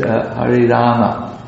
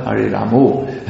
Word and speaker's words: Rama: No hissing sound, Hare Krishna, shu, shu, shu, shu, shu, that it, and Hari Rama, not Hari Ramo. That should be understood.